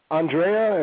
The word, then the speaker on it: Andrieh: Andrieh, and the last name Andrieh.